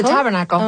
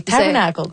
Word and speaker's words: Tabernacle: Comment on la prononce, cette salle-là? – What's it called? – Le Tabernacle. – The Tabernacle. Oh, the Tabernacle. Parfait.